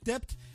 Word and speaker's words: debt: You keep saying Dept with a B. It's the B is silent, bro. – We'll ask him, is it a strike debt or is it a strike debt? – Okay.